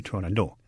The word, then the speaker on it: Turandot: And I believe that the argument is that the Persian pronunciation of the name is without the T, so you would say Turandot. Lotfi, who was the director, who was Persian, said it's an Italian opera, so he was going to pronounce it with the T. The conductor, Eduardo Müller, said, yes, pronounce the T, but do it very, very lightly.